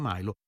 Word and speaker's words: Milo: tra l'altro interpretato da un italo americano che si chiama Milo, anzi Milo, il nome lo pronunciamo Milo, era ancora vivo.